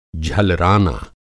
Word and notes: झलराना: Hindi

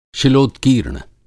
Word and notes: शिलोत्कीर्ण: Hindi

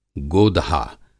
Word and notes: गोधा: Hindi

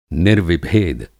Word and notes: निर्विभेद: Hindi

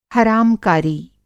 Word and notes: हरामकारी: حرام کاری

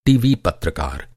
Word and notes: पत्रकार: Hindi

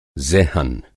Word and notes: ज़ेहन: ذہن

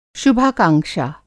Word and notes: शुभाकांक्षा: Hindi

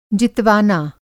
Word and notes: जितवाना: Hindi

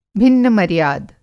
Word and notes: भिन्न-मर्याद: भिन्नमर्याद

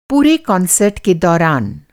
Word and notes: कंसर्ट: masculine noun

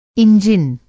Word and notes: इंजिन: اِنجن

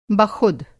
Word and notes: बख़ुद: بخود; Hindi

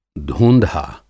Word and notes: धोंधा: Hindi

